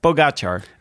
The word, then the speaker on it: Pogačar: Ik was naar Eurosport aan het kijken en daar legden ze de klemtoon op de ga. – Waarom was je nou... – Pogačar. – Pogačar. – Pogačar. – Pogačar. Nee, Pogačar.